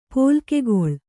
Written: ಪೋಲ್ಕೆಗೊಳ್

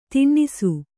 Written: ದಿನಸಿ

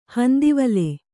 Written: ಹಂದಿವಲೆ

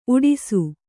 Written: ಊಡಿಸು

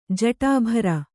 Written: ಜಟಾಭರ

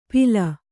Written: ಪಿಲ

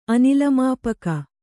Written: ಅನಿಲಮಾಪಕ

